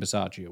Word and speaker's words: Bisaccia: Well, we hired him, and you pronounce his name Bisaccia, which I guess it's always one of those things where once you find out, it's like, I should have known that.